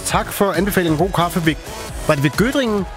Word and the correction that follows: Göttingen: Nej, Göttingen. – Göttingen, ja, men det er... – Göttingen eller ost. – Jamen, det er bare mig, der ikke kan udtale det korrekt.